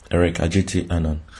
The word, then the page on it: Eric Adjetey Anang: prononciation